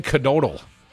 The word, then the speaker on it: Knodel: And your boy, Dan Knodel, and I'm pretty sure you pronounced the K, Dan Knodel says he would consider impeaching Janet Protasiewicz if he was elected to the Senate.